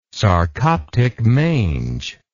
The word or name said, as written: sarcoptic mange